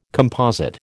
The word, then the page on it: composite: American English